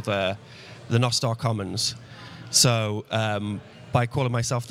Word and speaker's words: Nostr: co-founders of Nostr. That's the way you pronounce it.